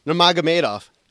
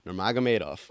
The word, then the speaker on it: Nurmagomedov: How you pronounce his last name? Namagov? – Nurmagomedov. And I'm so glad that I finally learned to say that.